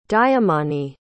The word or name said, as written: dyumani